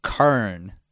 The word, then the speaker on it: Quorn: What I'm bringing is Kurn, is how I pronounce it. It's not how you say it, but it's corn spelled Q-U-O-R-N.